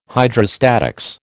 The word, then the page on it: Hydrostatics: American English